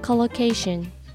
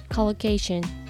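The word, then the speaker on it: collocation: コロケーションコロケーションローの発音は L ですちなみにコロケーションよく一緒に使われる単語の組み合わせを意味する単語です。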